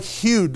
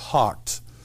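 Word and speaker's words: houghed: It looks like Hugh, doesn't it? Yeah. But that's not how it's pronounced. It's pronounced hawked.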